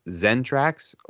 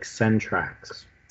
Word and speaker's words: Centrax: I wasn't sure if it was Zentrax or Centrax. – I've seen it both spelled with C and S, but it's like Centrax.